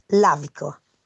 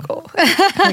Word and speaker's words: Lavico: I'm sorry, but the pronunciation is Lavico. – So it's Lavico. – Lavico. – You got it? Lavico.